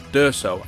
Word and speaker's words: D'Urso: Thanks to Andy and Laurie for being with us and correcting pronunciation of Andy. What was it now? D'Urso. Andy D'Urso. D'Urso. – Nearly got it wrong again then.